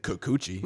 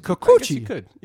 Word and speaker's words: Kikuchi: And you can't say Kikuchi. – No, it's Kikuchi. – You could say it that way too.